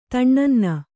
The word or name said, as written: ತಣ್ಣನ್ನ